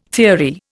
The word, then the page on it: theory: Indian